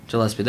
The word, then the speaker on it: Gillespie: Jeremy Glipspie or some shit. I don't know. – Gillespie? – Gillespie, there we go.